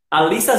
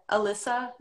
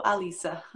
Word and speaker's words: Alyssa: O, como, como é que se pronuncia seu nome? Alissa Zantelo. Em inglês é Alyssa Zantelo, mas todos aí falam Alyssa, Então, para vocês, eu sou Alyssa.